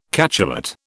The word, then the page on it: cachalot: British English